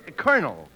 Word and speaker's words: colonel: No, you see, that word is pronounced colonel.